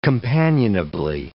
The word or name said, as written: companionably